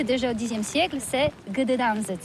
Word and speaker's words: Gdańsk: Oui, c'est pas très facile pour des étrangers, mais remarque bien que la langue polonaise, c'est la langue où on prononce chaque lettre qu'on écrit. C'est pourquoi on dit Gdansk.